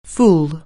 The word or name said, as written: fool